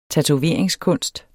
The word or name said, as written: tatoveringskunst